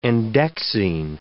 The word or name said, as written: endexine